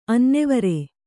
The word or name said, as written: ಅನ್ನೆವರ